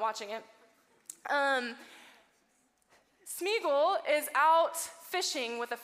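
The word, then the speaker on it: Sméagol: So this is Schmiegel, right? Cutter, did I say his name right? Smeagol. Oh, thank you. This is Smeagol.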